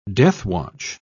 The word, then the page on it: deathwatch: British English